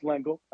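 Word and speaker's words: Lengyel: that Y at the that Y and that in the second part of his name is confusing. So yeah, but it's pronounced Lingle.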